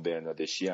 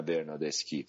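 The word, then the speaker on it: Bernardeschi: همون برنادشی هستش نه برنادسکی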